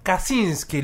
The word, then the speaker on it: Kaczyński: Vamos a contar la historia, o nos vas a contar la historia de Fiódor Kaczyński. ¿Lo dije bien? – Sí, perfecto, muy bien.